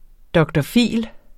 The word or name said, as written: dr.phil.